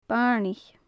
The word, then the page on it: bairneach: gaelic